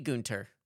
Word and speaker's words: Günther: I think it might be Günther. – Günther? – Yeah. – I never know how to pronounce the TH in German. – I think it's just... – like a T. – Yeah, I think so.